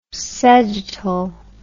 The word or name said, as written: segetal